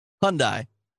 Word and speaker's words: Hyundai: Hyundai.